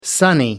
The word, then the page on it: sunny: American English